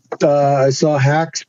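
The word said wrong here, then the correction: Haack: punt. – Yep. – Hawk. Sorry. – Yeah, no worries. – I forgot my pronunciation.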